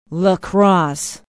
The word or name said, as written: La Crosse